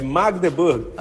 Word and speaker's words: Magdeburg: A pronúncia correta, é Magdeburg.